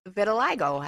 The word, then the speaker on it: vitiligo: Michael Jackson told Oprah Winfrey his light skin was caused by a disorder called vitiligo.